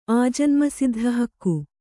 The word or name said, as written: ಆಜನ್ಮಸಿದ್ಧಹಕ್ಕು